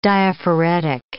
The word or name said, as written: diaphoretic